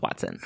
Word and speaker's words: hoatzin: I don't speak that language, but I went on Merriam Webster and I listened to how they pronounce it on that website, and I'm going to go with Watson.